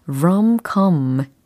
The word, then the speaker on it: rom-com: rom-com, rom-com 모음 부분의 발음이 좀 다른 거 눈치채셨나요? 특히 ROM, COM이니까 공통적으로 OO가 있기는 하나. 앞에 있는 O의 발음은 아오 이렇게 됐고 두 번째 O는 아, 이렇게 됐어요. 그래서 rom-com, rom-com, 요렇게, 예, 모음 부분이 달리 발음이 되었다는 것도, 예, 팁으로 전달해 드립니다.